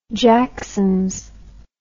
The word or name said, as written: jacksons